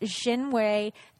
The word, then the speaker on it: Xinhui: Now, this is a satellite, and Spacecom had done a merger deal last month with a Chinese firm called, I'm probably going to say it wrong, but Xinhui Technology Group. – That actually sounded pretty good.